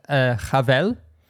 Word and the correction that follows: Chalev: What's that relationship? Like, how... – Chalev, isn't it? – Oh, sorry. Yeah, sorry. Glenn Chalev.